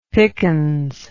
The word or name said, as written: pickens